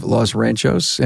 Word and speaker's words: Los Ranchos: And Dustin Brown from Brown Auto Experts, 3 locations in New Mexico, Albuquerque, in the village of Los Ranchos, and Rio Rancho. Did I say that right? – That's it.